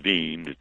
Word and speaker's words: Wien: The German pronunciation for Vienna is Wien. They spell it W-E-I-N and pronounce it Wien.